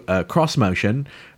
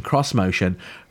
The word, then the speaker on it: Xmotion: The other car that I think is off the hook is the new CrossMotion, and it's spelled XMOTION, CrossMotion.